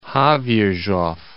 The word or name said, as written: Havirov